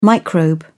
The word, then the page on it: microbe: British English